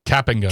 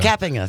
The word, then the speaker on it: Kapinga: Kapinga, thank you. We're finally going to say it correctly. – This is I will say this again to his face when he's on the show on Friday, but this is an apology to Dan Schaefer, who on the air said he was pronouncing Chris Kapinga's name incorrectly, because we've been saying Kapinga. – Kapinga, which is not it. – No one has corrected us. – Yeah, that's not it. It's a Kapinga.